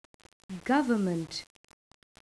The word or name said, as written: government